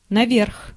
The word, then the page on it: наверх: /nɐˈvʲerx/; naverh